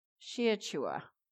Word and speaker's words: shachah: The Hebrew word for worship is spelt S-H-A-C-H-A-H, and that's pronounced Sheachua.